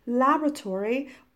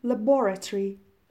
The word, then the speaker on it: laboratory: Set up a laboratory means established a, uh, laboratory, and uh, American pronunciation is laboratory, but British pronunciation is laboratory, laboratory. And when you learn the word basement, it is good to learn another word cellar, C E L L A R.